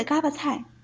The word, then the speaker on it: 锅巴菜: 别着急嘛，所谓的嘎巴菜其实写作锅巴菜，只是在天津话当中出现了读音与文字不同的情况。